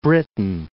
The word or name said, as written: Great Britain